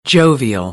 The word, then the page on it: jovial: American English; oʊ